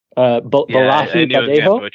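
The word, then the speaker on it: Bolaji Badejo: This one I'm unfortunately probably going to mispronounce. Bodea Banjo. – Hang on. Yep. The gentleman who played the alien himself, it's Nigerian actor, Balahi Badejo, I believe.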